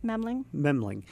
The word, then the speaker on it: Memling: One, and forgive the pronunciation here, Hans Memling. – Memling.